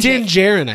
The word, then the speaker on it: Djarin: Is it that how we're saying? Is it Din Djarin? It's Din Djarin, I think. I've been saying Din Djarin.